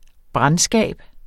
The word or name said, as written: brandskab